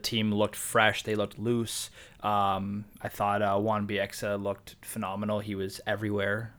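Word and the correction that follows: Wan-Bissaka: Juan Bissaka. It continues. We came back from the break, and he still can't pronounce names.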